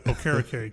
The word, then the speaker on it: Okereke: I always get his name wrong. I was like, what was the first way we said it? – I'm not going to say that because then I'll default to say that. Okereke.